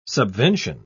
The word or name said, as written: subvention